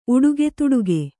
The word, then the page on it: ಉಡುಗೆತುಡುಗೆ: Kannada